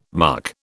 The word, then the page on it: muck: British English